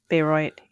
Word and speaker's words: Bayreuth: Do you know, how does that pronounce? – I think it's like Bayreuth. – Bayreuth. Yeah, that makes sense with the German.